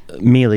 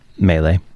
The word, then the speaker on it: Mele: No, Mele is how we pronounce Mele. – It. – Mele. So I've been saying it all wrong, and you haven't corrected me. – That's the proper way to say it.